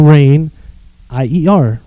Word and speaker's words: Rainier: I said, "Well, first of all, that's how you pronounce Rainier, spelled R-A-I-N like rain, I-E-R, Rainier."